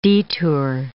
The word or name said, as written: detour